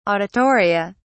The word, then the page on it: auditoria: British English